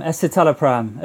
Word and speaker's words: Escitalopram: It is, how do you pronounce this word? Escitalopram? – Escitalopram. At least that's how I say it.